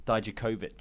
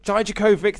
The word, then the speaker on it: Dijakovic: Then we got the in-ring debut of Dominik Dijakovic. Dijakovic. – Dijakovic. – Dijakovic. Thank you. I've even written it out like phonetically, and I'm still getting it wrong.